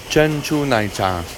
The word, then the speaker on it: 珍珠奶茶: 珍珠奶茶. – 珍珠奶茶.